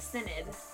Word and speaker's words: synod: Convened a synod. It's pronounced synod. It's definitely pronounced synod.